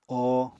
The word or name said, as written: o